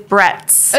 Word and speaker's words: Bretz: It is Bretz.